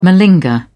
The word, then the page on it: malinger: British English